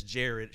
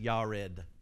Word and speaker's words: Jared: Mahalalel lived 65 years, and he begat, English says Jared, it should be pronounced Yared. Yared. But I'm going to humor everybody here and call him Jared.